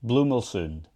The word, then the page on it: Bluemull Sound: Shaetlan